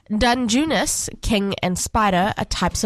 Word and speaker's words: Dungeness: Dungeness crabs.